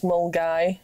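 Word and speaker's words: Milngavie: Or there's Mulgai, which is Mulngavy, but that's near Glasgow.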